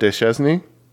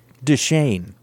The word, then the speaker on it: Deschesne: Richard Starkings on letters with Albert Deschesny. That's pretty amazing. I believe that's pronounced Deshane. Deshane? Deshane. It's not as much fun as Deschesny.